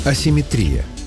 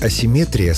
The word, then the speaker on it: асимметрия: Вариант асимметрия словарями не поддерживается и считается ошибочным. Так что в русском языке симметрия есть, а вот асимметрии нет. Запомнить легко. Понятие «симметрия» — это когда все ровно и по центру. Даже ударение стоит по центру слова. А вот асимметрия уже с нарушением баланса, поэтому и ударение съезжает к концу.